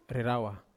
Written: rerawa

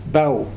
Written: Pau

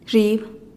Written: zhiib

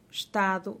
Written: xtadu

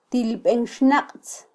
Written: tilbenxnaqtz

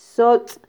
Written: sotz

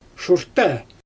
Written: ʃurttæ

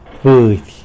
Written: budy

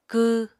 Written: kw

